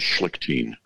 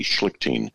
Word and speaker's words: Schlichting: Yeah, our surname in our family history is Schlichtin. S-C-H-L-I-C-H-T-I-N-G, Schlichtin. And you say it like in a German way, Schlichtin.